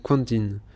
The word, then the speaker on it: Quentin: C'est bête, mais Tarantino, il s'appelle Quentin. Mais ça se prononce comme en américain.